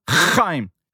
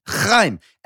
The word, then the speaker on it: Haim: It's pronounced Haim. – Haim.